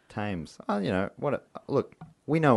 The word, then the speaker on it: Thames: I'm going to go drown myself in the Thames. I fucked up saying it wrong. – That's what I'm... Oh. – Thames. God damn it. – You got it half right.